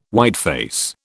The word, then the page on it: whiteface: British English